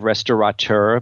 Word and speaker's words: restaurateur: Congratulations on the correct pronunciation of restaurateur, by the way. So many people put an N in that.